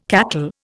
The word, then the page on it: cackle: Indian